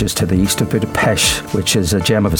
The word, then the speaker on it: Budapest: Well, most people say Budapest, you know, but it's Budapest. Exactly. – We have to say what the locals say.